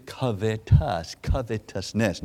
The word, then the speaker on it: covetousness: And he showed me from the dictionary the way to pronounce it is covetousness.